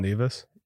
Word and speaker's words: Nevis: It's called Nevis. – Nevis. I don't know. We call it Nevis. It's spelled the same way as the small Minnesota town.